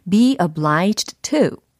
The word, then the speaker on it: obliged: be obliged to, be obliged to. 연음 처리가 되니까 예, obliged 이렇게까지는 발음이 되지 않습니다.